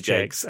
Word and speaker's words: Jakes: It's spelt like Jacks, but it's definitely Jakes.